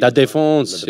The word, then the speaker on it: La Défense: Yeah, it's a good pronunciation of the stadium. – La Défense, I prefer U Arena.